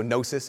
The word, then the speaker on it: gnosis: Ignore comes from the Greek word gnosis, or it's silent G, so gnosis.